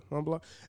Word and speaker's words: Montblanc: For me, it'd be Light Blue from Dolce & Gabbana and Legend from, how do I pronounce it, Montblanc? – Yeah, that's how you pronounce it. – Yeah. – Yeah, Montblanc.